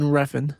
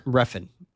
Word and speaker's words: Refn: He also cited Nicholas Winding, how do you say his last name? – Nicholas Winding Refn. – Refn.